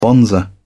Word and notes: bonzer: British English

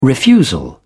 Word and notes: refusal: British English

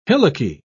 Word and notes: hillocky: British English

